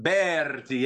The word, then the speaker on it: Berti: I pronounced that name right, right? – Berti. Yeah, just some people can't roll the R's.